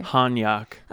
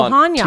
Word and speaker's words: Hanyok: Wait, how do you say it? – Hanyok. – Oh, Hanyok. – Two syllables.